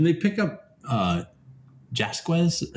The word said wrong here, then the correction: Jaquez: Before you start saying it wrong over and over again, it's Jaquez.